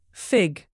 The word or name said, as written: fig